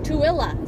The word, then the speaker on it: Tooele: Tooele is how they pronounce it.